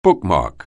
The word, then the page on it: bookmark: anglais